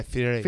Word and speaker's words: Fieri: Also, can you please pronounce his name right? It's Guy Fieri. – Fieri.